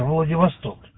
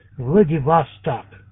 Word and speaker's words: Владивосток: Надо делать 2 ударения в названии этого города. Владивосток. Вот, правильно, Владивосток. Как бы 2 ударения на 2 слога.